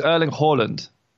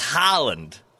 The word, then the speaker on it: Erling Haaland: It's Erling Haaland. – Interesting, didn't know that. Yeah. I'm pretty sure it's Haaland.